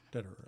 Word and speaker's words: Dederer: Am I saying that right? – Mm-hmm. – Dederer. – Mm-hmm.